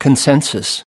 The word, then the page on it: consensus: American English